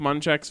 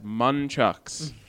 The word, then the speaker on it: Munchak: A pair of nunchucks with Mike Munchak's face on them, branded as Munchucks. – Because he kept mispronouncing Mike Munchak's name in his press availability. It said Munchuck.